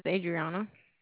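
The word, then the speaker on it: Adrianna: My name is Adriana. – Adriana? – Mm-hmm. – Well, how do you spell it? A-D-R? – I-A-N-N-A.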